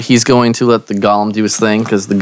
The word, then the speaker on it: golem: It doesn't matter. The word is pronounced golem.